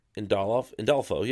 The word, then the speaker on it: Andolfo: And illustrated by Mirka Andolfo. Andolfo, yeah, I said it right the first time.